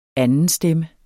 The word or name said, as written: andenstemme